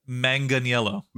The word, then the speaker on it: Manganiello: Manganiello, did I say it right? – Manganiello.